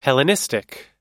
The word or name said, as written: hellenistic